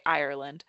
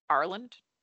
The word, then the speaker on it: Ireland: So like for us, it's essentially three syllables, right? Ireland. For them, it's two. So it's just like Ireland.